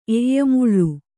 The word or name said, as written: ಎಯ್ಯಮುಳ್ಳು